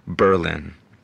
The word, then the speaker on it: Berlin: It's called Berlin. And yeah, that's how you pronounce it.